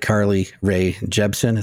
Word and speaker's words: Carly Rae Jepsen: Like Carly Rae Jepsen.